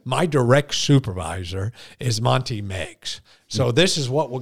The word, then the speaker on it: Meigs: Meigs, spelled M-E-I-G-S, pronounced Meigs.